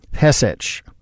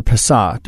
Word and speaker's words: Pesach: Pesach, or Pesach, depending on how you want to pronounce it.